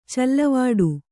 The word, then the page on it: ಚಲ್ಲುವಡು: calluvaḍu